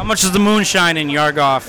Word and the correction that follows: Yuggoth: Am I saying that right? What the Yuggoth. Fuck are you saying? Yuggoth? – Yuggoth?